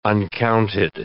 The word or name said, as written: uncounted